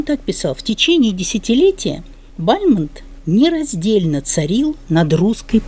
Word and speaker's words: Бальмонт: Хотя его чаще произносят Бальмонт, потому что завершил он свою жизнь в Париже, о нем говорили по-французски, и, конечно, Бальмонт, Бальмонт, но я читала специальную статью, где говорилось, что вообще-то он Бальмонт.